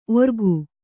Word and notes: ಓರ್ಗೈ: Kannada

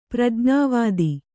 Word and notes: ಪ್ರಜ್ಞಾವಾದಿ: prajñāvādi

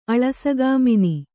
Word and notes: ಅಳಸಗಾಮಿನಿ: Kannada

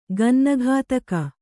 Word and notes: ಗನ್ನಗತಕ: gannagataka

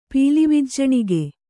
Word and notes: ಪೀಲಿವಿಜ್ಜಣಿಗೆ: Kannada

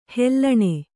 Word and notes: ಹಲ್ಲಣೆ: hallaṇe

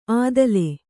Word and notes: ಅದ್ಲೆ: adle

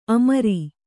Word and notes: ಅಮರು: Kannada

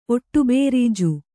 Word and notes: ಒಟ್ಟುಬೇರೀಜು: Kannada

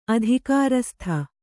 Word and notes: ಅಧಿಕಾರಸ್ಥ: kannada

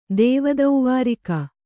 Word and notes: ದೇವದೌವಾರಿಕ: kannada

